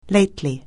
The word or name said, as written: lately